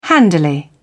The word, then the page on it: handily: British English